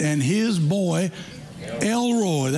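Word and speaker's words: El Roi: It's a Hebrew name and it's L-E-L and then capital R-O-I. Now that's pronounced Elroy.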